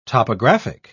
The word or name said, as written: topographic